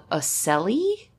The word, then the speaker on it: ocelli: Yeah, these are called ocelli. I think I'm pronouncing that right. It's spelled O-C-E-L-L-I, ocelli.